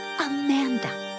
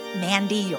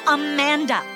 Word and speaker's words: Amanda: Amanda. I don't go for all that shortening Mandy or Manda, and definitely not man. It's Amanda.